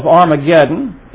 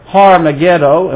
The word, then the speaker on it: Armageddon: We know that there's going to be a great battle called the Battle of Armageddon. It's going to be in the Valley of Harmageddon, and we pronounce it Armageddon.